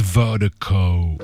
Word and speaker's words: Virdiko: And Vertigo is spelled V-I-R-D-I-K-O. That's V-I-R, dang, I can't speak. That's V-I-R-D-I-K-O. Pronounced Vertigo.